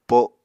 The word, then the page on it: po7: Apatani